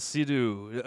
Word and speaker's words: Sea-Doo: Like, it's a Sea-Doo. – There's no space in between. – It's one word. – It's almost one syllabus. Like, yeah. Or one syllable.